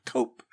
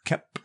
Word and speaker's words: Koepp: I found out that is the way to pronounce his name. – Koepp. – I, for most of my years, have said Cope. But it is kept, and I don't think we need to really go into a huge synopsis of this.